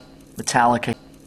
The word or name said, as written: Metallica